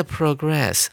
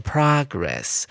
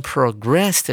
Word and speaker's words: progress: 这边的 progress 啊，不能念成 progress。 progress 是名词的用法，指的是进步、进展的概念。而念成 progress 的时候，重音在第二音节，它是当做动词来使用。